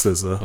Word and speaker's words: SZA: And I hope that's how you pronounce her name. I don't fucking know. – Yeah, it's SZA.